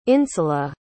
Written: insula

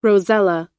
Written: rosella

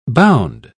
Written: bound

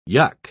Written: yuck

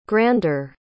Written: grander

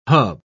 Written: hub

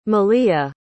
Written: maliha

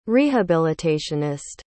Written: rehabilitationist

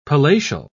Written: palatial

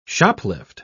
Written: shoplift

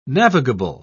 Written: navigable

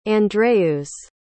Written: Andreus

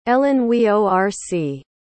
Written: ellenweorc

